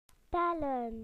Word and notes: to count: British English

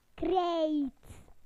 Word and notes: chalk: British English

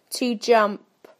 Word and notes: to jump: British English

